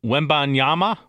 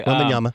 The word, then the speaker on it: Wembanyama: Wembanyama. Yeah. Okay. – Wembanyama.